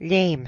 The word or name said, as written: Léim